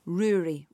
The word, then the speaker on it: Rory: It's from Rory, rhymes with brewery.